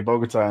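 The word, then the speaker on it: Bogotá: I think, I don't know how to say it, Bodega in Colombia. It starts Bogota. Like there. Yeah, Bogota in that line.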